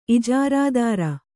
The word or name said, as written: ಇಜಾರಾದಾರ